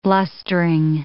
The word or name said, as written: blustering